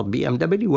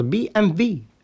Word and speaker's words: BMW: أي أن BMW كما نلفظها نحن عربيا وربما حتى الإنجليز حتى في اللغة الإنجليزية تلفظ بي ام دبليو، ولكنها فعليا في الألمانية ولغات أخرى تلفظ بي ام في،